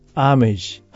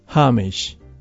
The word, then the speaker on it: homage: スペルは H-O-M-A-G-E。homage と書いてアーメージ、ハーメージ。